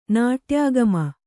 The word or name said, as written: ನಾಟ್ಯಾಗಮ